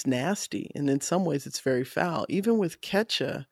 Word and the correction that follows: Kesha: I hope I'm pronouncing her name correctly. Kesha. I'm sorry. – I think it was Kesha. – Yeah. It's Kesha.